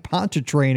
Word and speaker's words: Pontchartrain: So we're heading down to New Orleans, the Lake Pontchartrain area. I think I said that right, with Lister Steven. Steven, thanks for coming on the show today. – Thanks for having me. You absolutely did say it right.